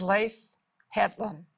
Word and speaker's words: Leif Hetland: And it is Leif Hetland. Am I saying it properly? – Yep.